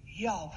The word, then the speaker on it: Yahweh: So we pronounce it Yahweh. Like a breath, you You told Moses that your name is Yahweh.